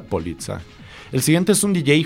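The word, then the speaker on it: Poliça: Hagan de cuenta que se escribe como Polica, pero en lugar de la C normal que nosotros usamos, se usa la C que usa normalmente el lenguaje portugués.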